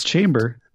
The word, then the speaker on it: Chamber: It's Chamber.